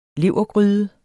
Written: levergryde

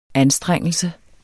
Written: anstrengelse

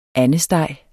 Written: andesteg

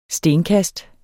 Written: stenkast

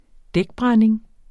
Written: dækbrænding

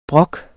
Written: brok

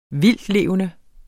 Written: vildtlevende

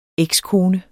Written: ekskone

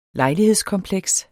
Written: lejlighedskompleks

